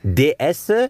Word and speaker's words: DS: DS heißt ja auf Französisch oder soll auf Französisch die Göttin heißen. Deswegen dieses Wortspiel DS. Buchstabe, spricht sich halt die Göttin aus auf Französisch.